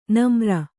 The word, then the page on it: ನಮ್ರ: namra